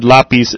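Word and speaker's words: lápiz: So you have the accent on the A because the stress when you say the word lapis is on the A.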